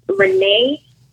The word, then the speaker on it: Renae: So my first name, Alexis Renee X, and Renee is spelled R-E-N-A-E.